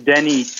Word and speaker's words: Danny: So that's just my name, Danny Kortenrade. I can also spell it because it is a difficult name. And the same is my IG. So Danny, D-A-N-N-Y, and my last name is Kortenrade.